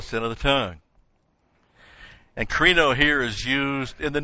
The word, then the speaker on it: krino: In fact, the word krino, K-R-I-N, long O, krino, means to judge someone.